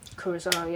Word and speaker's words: Kurosawa: Kurosawa, yeah, that's the, well, I can't pronounce his name, but the Asian guy, Last Samurai. – Kurosawa, yeah.